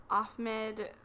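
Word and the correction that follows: Ahmaud: Yeah, I think it's Ahmad. – Ahmad, sorry.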